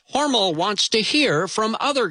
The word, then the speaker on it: Hormel: Yes, the company name is actually pronounced Hormel, not Hormel, but I digress.